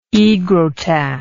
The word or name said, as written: aegrotat